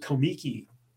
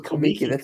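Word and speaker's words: Comique: Arbuckle pronounced it Komiki, and so it was that was the Komiki company. And so I've always called it Komiki, but when Arbuckle got into trouble, they changed the name of the company to Buster Keaton Productions Incorporated, right.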